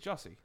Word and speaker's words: Jussie: I think it's Jussie. – I always thought it was Yussie or Jussie. – It's not like yogging, you idiot.